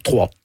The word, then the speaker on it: Troyes: Vous commencez à jouer, bon, vous êtes né à Troyes, on dit Troyes, en France. Comment on prononce ? – Trois. – Trois. Ben voilà, ça s'écrit YES en France.